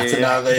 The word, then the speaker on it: Natanavā: So Natanavā, is that the correct pronunciation? Yeah, yeah, you don't yeah. it. Yeah. Natanavā, yeah. yeah.